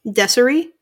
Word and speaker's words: Desiree: D-E-S-I-R-E. – How do you say it? – Desiree.